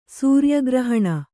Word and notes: ಸೂರಿಯಗ್ರಹಣ: sūriya grahaṇa; Kannada

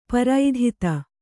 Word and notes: ಪ್ರಥಿತ: Kannada; prathita

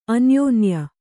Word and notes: ಅನ್ಯೋನ್ಯ: anyōnya

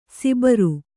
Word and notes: ಸಿಬರು: sibaru

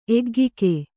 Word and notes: ಎಗ್ಗಿಕೆ: kannada

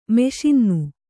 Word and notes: ಮಿಷನ್ನು: miṣannu

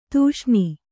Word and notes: ಧಿಷಣೆ: dhish'aṇe

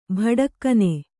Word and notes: ಭಡಕ್ಕನೆ: Bhaḍakkane

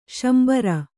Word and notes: ಶಾಂಬರ: śambara

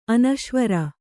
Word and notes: ಅನೀಶ್ವರ: anīśvara

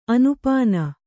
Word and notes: ಅನುಪಾನ: anupāna

